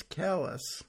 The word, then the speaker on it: Calais: You would think it would be Calais, but no, it is Calais.